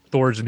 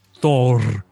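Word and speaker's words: Þór: I was going to say, John, do you know how to pronounce Thor's name in Icelandic? – I do not know. – Thor. – Oh, is you got to roll the R.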